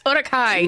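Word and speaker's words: Uruk-hai: That's how you say it, Tim. It's Urukai.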